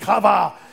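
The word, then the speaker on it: qavah: It's pronounced kava. It's Q-A-V-A-H, but it's kava.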